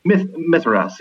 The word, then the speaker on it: Mithras: How do you pronounce it? Is it Mithras? – Mithras, yeah.